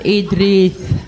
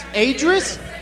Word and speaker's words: Idris: Adris? Adris? – Where are you going? – It's a fucking I at the beginning.